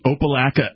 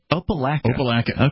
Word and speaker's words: Opelika: Yes, from Opelika, Alabama. – Is that how you pronounce that? – Yes. – Opelika.